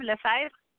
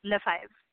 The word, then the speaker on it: Lefebvre: But if you want to learn a little French, I'll tell you my maiden name, Lefebvre. It's pronounced like number five, so that's the closest thing. Yes, Lefebvre Connolly.